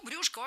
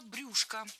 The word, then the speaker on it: брюшко: Дважды прозвучало брюшко. И это, в принципе, правильно. Сейчас такой вариант стал литературным. Однако, буквально год назад приоритетным было ударение на окончании – брюшко. Но теперь за этим словом закреплена двойная норма. Так что можно говорить и брюшко, и брюшко.